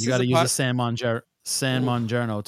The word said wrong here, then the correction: San Marzano: I didn't even say it right. San Marzano.